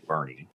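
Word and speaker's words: Boerne: Bernie. – I'm glad you pronounced that correctly. – Thank you. Thank you. I appreciate that. – It's not called Bourne, Texas.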